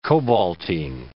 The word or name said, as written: cobaltine